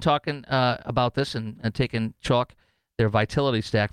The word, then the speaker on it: Choq: Thankfully, the Patriots at Chalk, spelled C-H-O-Q, pronounced chalk, but C-H-O-Q.